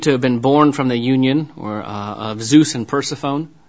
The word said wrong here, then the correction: Persephone: Persephone. There we go. I'll get it. I'll actually put the enunciation on the correct syllable. Persephone,